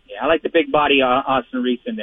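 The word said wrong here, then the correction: Aston-Reese: I think that would be Aston Reese, sir. Don't mispronounce his name. Aston Reese.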